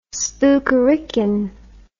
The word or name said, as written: spookerican